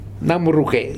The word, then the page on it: Namruke: Futuna